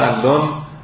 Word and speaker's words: علان: ولی به لهجه محلی اینا علان تلفظ میشده و مشهور به اون میشدن.